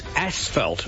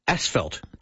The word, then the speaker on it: asphalt: It's asphalt. There's no S-H. It's spelled A-S-P-H-A-L-T, asphalt.